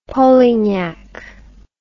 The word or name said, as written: polignac